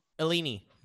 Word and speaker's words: Illini: Illini. – Fighting Illini. – Illini. – The Eni, so if it's Houdini, this is Illini.